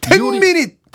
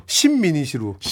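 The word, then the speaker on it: 10 Minutes: 나 옛날에 이거 잘못 읽었잖아. – 어떻게요? – 십미닛으로. – 십미닛. – 십자가 쓰여 있어가지고 십미닛. – 십미닛. – 그래서 사람들 이런 무식한 놈이 텐미닛이라고 읽어야지 십미닛이 뭐니?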